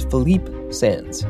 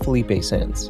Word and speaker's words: Philippe Sands: It is Philippe Sands, not Felipe Sands.